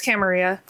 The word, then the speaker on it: Camarilla: Am I saying that right? I'm not saying that right. – It's like weird that... – Camarilla. – Okay. Tappy went on a whole thing. – It's Camarilla. – Thank you.